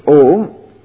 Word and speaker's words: Om: so A, U and Ma, because A and U, Sandhi becomes O, so Om. One who meditates, repeating Om and knowing all the three,